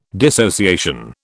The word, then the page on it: dissociation: British English